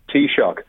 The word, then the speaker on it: Taoiseach: I remember explaining to the British government pre-Good Friday Agreement how you pronounce Taoiseach. Now, you and I are well used to saying Taoiseach, but I had to write down the word tea, cup of tea, yeah, and shock, electric shock, Taoiseach.